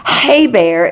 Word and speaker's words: Heber: But just so you know, Heber is the way they pronounce it in the Hebrew, and that's where the Hebrew line started.